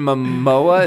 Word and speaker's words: Momoa: I always thought he looked like, what's that guy, Jason Momoa?